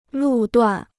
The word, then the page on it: 路段: lù duàn